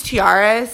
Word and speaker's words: Tiara: My name is Tiara, spelled like the crowns that princesses wear.